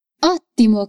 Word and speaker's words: ottimo: Atenção à pronúncia. Ótimo. Così stacco un um po' dallo studio. Ottimo, così stacco un po' dallo studio.